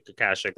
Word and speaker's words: Akashic: I have some stuff to say about it just because I knew we were going to do the Akashaka Records with Jen. Sorry, did I say that wrong? – No, you said it wrong. – Akashic, that's pretty close.